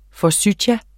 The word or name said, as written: Forsythia